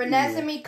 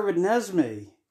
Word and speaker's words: Renesmee: No, they call her Renesmee. You said Renesmee. Like you're saying resume.